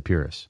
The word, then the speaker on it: Pyrrhus: And you reference Pyrrhus. I'm going to say Pyrrhus. – That's the way I've pronounced it simply because of the term he is associated with, the Pyrrhic victory.